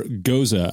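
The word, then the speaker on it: gose: But this is a sour goza. I recently figured out how to say that. I saw it pronounced or written out phonetically.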